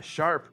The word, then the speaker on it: Sharpe: The thing is, Chris, his name is uh, Sharp, but it's spelled S H A R P E.